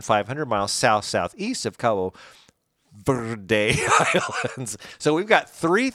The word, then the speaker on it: Verde: I do know it's Verde because that's how you say it in Spanish. – Well, it is. – Verde. Do you have to roll the R? – Of course.